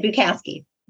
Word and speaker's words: Bukowski: Bukowski.